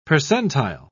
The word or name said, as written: percentile